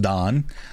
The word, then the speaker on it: Dan: Dan, mm-hmm. pronounced Don, he also won an Emmy.